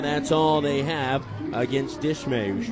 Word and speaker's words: Dishmey: Eliezer spelled E-L-I-A-Z-A-R. Dishmay, D-I-S-H-M-E-Y. But it's pronounced Dishmay.